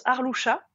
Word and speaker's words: Arlusia: Alors mon prénom se prononce Arloucha, mais il s'écrit Arlusia.